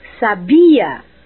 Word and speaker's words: sabia: Por exemplo, sábia, com acento no A, significa inteligente. Sabia, com a sílaba tônica na segunda sílaba, é do verbo saber. E sabiá, com acento na última sílaba, é o pássaro.